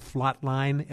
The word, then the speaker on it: Flot: I know you have a ministry that's called the, do I pronounce this right, the Flot Line, F-L-O-T line? – That's correct.